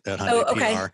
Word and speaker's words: Hyundai: Everyone already knows Hyundai. They don't always know how to pronounce it. It's like Sunday, but they know that it exists, and the awareness level of the Hyundai brand is I would imagine pretty high.